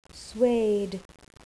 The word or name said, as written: suede